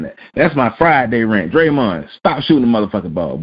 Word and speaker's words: Draymond: Just say no, Draymond, which rhymes with Raymond.